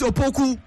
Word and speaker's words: Opoku: Remember how the players pronounce their names during the Opoku no Osi Opoku. Mata. It is Opoku. Masa. It is not a UK name. It is not a British name. It is a Ghanaian name.